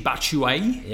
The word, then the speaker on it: Batshuayi: Michy Batshuayi? – Yeah, ooh, that was your best pronunciation ever.